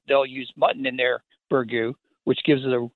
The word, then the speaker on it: Burgoo: Burgoo, yep, that's correct.